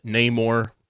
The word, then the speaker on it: Namor: Also, Dylan, Ryan Coogler says Namor, which is how Namor is pronounced in the movie.